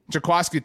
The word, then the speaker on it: Jaquiski: If Jaquiski Tartt, pronounce this correctly, right? – Jaquiski, thank you very much.